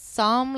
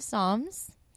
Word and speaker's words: Psalm: The P is silent. So it's Psalm, like the book of Psalms from the Bible, just in case you're not familiar.